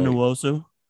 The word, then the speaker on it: Uchenna Nwosu: Maybe going and grabbing a guy like, how do you say his name? Nwosu. Is it Uchenna? – That's exactly Uchenna right. – Uchenna Nwosu.